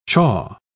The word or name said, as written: chaw